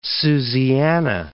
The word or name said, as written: Susiana